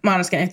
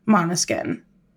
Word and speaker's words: Måneskin: Yeah, Am I pronouncing that right? – Måneskin. It's Dutch for moonlight. They're an Italian rock band, but the name comes from the Dutch word for moonlight. Måneskin.